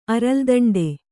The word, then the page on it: ಅರಲ್ದೋಂಟ: kannada